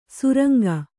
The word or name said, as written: ಸುರಂಗ